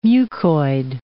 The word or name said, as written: mucoid